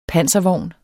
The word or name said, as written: panservogn